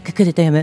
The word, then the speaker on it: 心: 心と書いてククルと読むククルの宿および養鶏場を営んでいる野波宗信さんです。